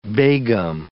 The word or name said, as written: begum